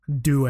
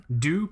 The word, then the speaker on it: Due: Due. It's D-U-E. So it's just the number 2 in Italian. – Due? Panerai Due? – Due. So D-U-E. – I'm not saying Due. I'm going to say Due.